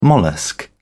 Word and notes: mollusc: British English

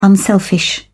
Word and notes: unselfish: British English

